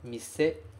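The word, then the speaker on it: mise (店): A pronúncia deste kanji é mise. Tá aqui em hiragana, tá aqui também em romaji.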